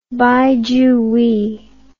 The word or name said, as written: Bai Juyi